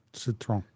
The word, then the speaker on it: Citroën: Citroën. – Citroën? Or is it just Citroën? – Citroën. Like a lemon. – It's spelled Citroën, isn't it? – Yeah. – But it's pronounced Citroën? I failed French, so.